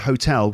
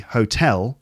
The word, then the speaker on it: hotel: He's talking about the fact that Hotel California seems to have made lots of people pronounce the word hotel with the wrong stress, because it should be hotel, and apparently in the song they sing hotel.